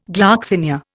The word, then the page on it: gloxinia: Indian